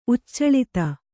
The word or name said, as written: ಉಚ್ಚಲಿತ